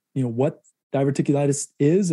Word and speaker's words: diverticulitis: So the diverticulitis, is that pronounced correctly? – Yep.